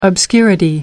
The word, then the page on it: obscurity: American English